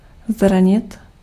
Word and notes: injure: American English; [ˈɪn.dʒɚ]

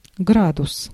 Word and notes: градус: /ˈɡradʊs/; gradus

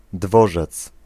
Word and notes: station: /ˈsteɪʃən/

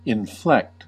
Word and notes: inflect: /ɪnˈflɛkt/

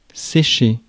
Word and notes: ditch: /dɪtʃ/

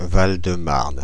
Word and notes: Val-de-Marne: IPA: [val də maʁn]; French; France (Paris)

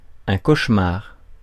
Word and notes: cauchemar: France; /koʃ.maʁ/

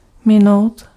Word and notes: rater: [ʁa.te]

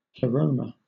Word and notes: pleroma: /plɪˈɹəʊmə/; /pləˈɹoʊmə/

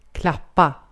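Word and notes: klappa: Swedish

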